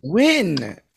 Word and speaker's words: Nguyen: It's pronounced Win, actually. – Win. Oh, like BoJack Horseman. I should have known that. Win. Dustin Win.